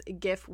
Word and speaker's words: GIF: okay, but you're just going to feel like very validated because according to the developer of the GIF himself, Steve Willett, it is GIF with a soft G. And he makes this joke about people who pronounce it with like a J.